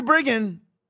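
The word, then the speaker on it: Bridgen: Hope I'm pronouncing it right because it's B-R-I-D-G-E-N, but I've heard it pronounced Briggen, so I hope that's it, not Bridgeton.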